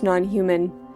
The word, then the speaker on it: NNHMN: We started that set off there with a track by Non-Human, who spell their name. N-N-H-M-N. But it is pronounced non-human.